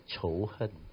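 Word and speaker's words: chouhen (仇恨): In Mandarin, it's pronounced as chouhen. Chouhen.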